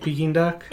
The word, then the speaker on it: Peking: Did you say Pekin? – Well, it's pronounced Peking duck. Peking or Peking? – No, it's Peking. Mm-hmm. All right. I mean, everything that I've ever seen pronounces it Peking duck.